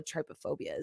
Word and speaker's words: trypophobia: Wasp nest, hornet's nest, also a trypophobia.